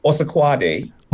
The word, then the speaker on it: Osakuade: My last name is pronounced as Osakuade.